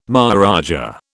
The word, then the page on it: maharaja: British English